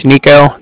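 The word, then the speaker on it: Niko: Like I said, my name is Nico. That's N-I-K-O. And I'm calling from the Bank of Cardiff. And that's spelled C-A-R-D-I-F-F, as in Frank, Frank.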